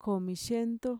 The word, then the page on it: komixiento: Mixtec